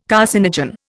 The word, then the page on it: carcinogen: Indian